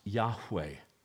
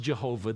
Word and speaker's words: Yahweh: And anywhere in the Old Testament, you see the word Lord, L-O-R-D, in all capital letters. It translates the Hebrew word YHWH. God's name is pronounced Yahweh. It's not pronounced Jehovah. They don't have a J or a V in the Hebrew alphabet. They couldn't have pronounced it that way.